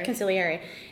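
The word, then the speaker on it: consigliere: Is that how you say it? – Conciliary.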